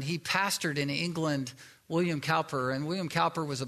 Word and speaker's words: Cowper: There was a poet who was named William Cowper, or William Kuyper, depending on how you pronounce it, from England.